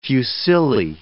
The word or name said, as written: fusilli